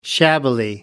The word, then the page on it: shabbily: British English